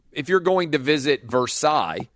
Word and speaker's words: Versailles: There's a town outside of Lexington, Kentucky called Versailles. They pronounce it Versailles, even though the town were named after Versailles.